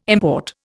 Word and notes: import: Indian

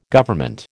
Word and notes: Government: American English

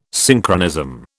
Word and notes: Synchronism: British English

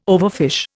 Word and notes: overfish: Indian

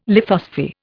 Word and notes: lithosphere: Indian

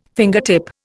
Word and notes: fingertip: Indian

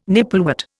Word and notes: nipplewort: Indian

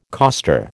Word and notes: coster: American English

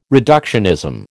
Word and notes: reductionism: American English

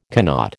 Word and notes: cannot: American English

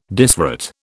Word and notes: disrate: British English

